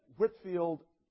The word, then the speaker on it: Whitefield: As I said, Whitfield, spelled Whitefield, but pronounced Whitfield, and Wesley were friends at Oxford, part of the Holy Club, or the Bible Moths, as they were nicknamed, or the Methodists.